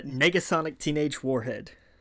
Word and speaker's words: Negasonic Teenage Warhead: Now, how do you pronounce her name? – That's a Negasonic Teenage Warhead.